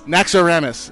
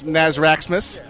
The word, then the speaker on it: Naxxramas: Naxxramas. Naxxramas, huh? Much better than last week, where it was Nazraxmas. Syphilis.